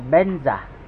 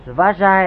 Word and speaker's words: Vajra: À, người Tây Tạng họ đọc là Benza. Benza tức là Vajra hay Vajra được.